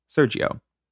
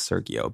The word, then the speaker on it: Sergio: So on Cedar Walk Drive, this is the home of Elizabeth Barraza, her husband, Sergio. I thought it was Sergio, but people are pronouncing it Sergio.